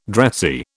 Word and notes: dressy: British English

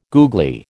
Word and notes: googly: American English

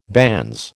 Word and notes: banns: American English

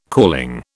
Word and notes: calling: British English

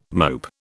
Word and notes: mope: British English

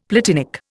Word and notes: platinic: Indian

